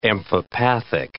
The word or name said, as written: amphipathic